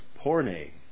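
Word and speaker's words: porne (πόρνη): Does anyone know how you pronounce the word harlot in Greek? I didn't know either, but I looked it up. And it's pronounced porne.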